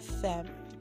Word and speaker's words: femmes: Pour les femmes. Okay, so P-O-U-R, pour, les, femmes, F-E-M-M-E-S. Let's see if I remember my French correctly that we don't pronounce the S. Right, femme.